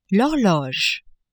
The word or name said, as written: horloge